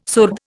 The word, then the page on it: surd: Indian